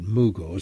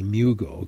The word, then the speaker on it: Mugo: This texter says, and I pronounce it Mugo, some people say Mugo, can Mugo pine shrubs be planted now? – You can.